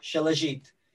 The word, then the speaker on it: Shilajit: And then I put like I put this thing called I don't know if I'm saying this right, but it's called Shilajit. – Yeah. – How do you say it? – Shilajit.